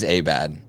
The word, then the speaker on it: Abad: And then somebody said his name's actually pronounced Patrick Abad. And we were like, shut up. No, no. And Kyle texts him and we were like, he's so gracious about it. And it is Abad.